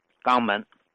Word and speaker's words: 江门: 比如啊，广东省有一个城市叫江门市，但当地的方言呢，把它读成叫“ 肛门”。